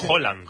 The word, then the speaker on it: Haaland: Como Haaland. Decir, flaco. – Por ejemplo. – Claro, Haaland... – Es Haaland. – Haaland. Como si dijeras un queso, ¿no? – ¿Sabes qué pasa el martes?